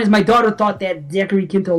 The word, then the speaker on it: Zachary Quinto: So anyway, the guy that plays Spock, Zachary Quinto, is that how you pronounce his name? – Yeah.